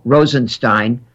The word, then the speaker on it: Rosenstein: The Trump administration may never recover from the decision of Deputy Attorney General Rob Rosenstein, and that's the correct pronunciation,